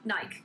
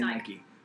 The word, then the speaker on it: Nike: Nike. – Yeah. – Yeah. – I'd say Nike. – Yeah, I say Nike too, but Brits, for some reason, say Nike. – Nike.